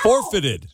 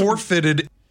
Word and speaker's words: forfeited: Forfeited. – You are putting the emphasis on the four and not the fitted.